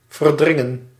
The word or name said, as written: jostle